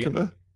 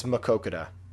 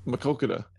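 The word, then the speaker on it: Maquoketa: Say Lakota? Lakota. He says Lakota. But it's Makokita. – Makokita.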